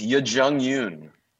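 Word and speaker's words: Yuh-jung Youn: You're the one in this group that can pronounce her name. – It's not hard. It's Yoo Jung Yoon.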